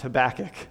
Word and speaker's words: Habakkuk: God loves you a whole lot, and this book is pronounced Habakkuk. So those are your notes.